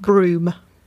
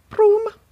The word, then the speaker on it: Broome: Broome. With an E on the end. Broome.